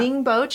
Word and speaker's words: Ningbo: And he is a master's student from Ningbo. Did I say that right? – Yeah. – Ningbo, China.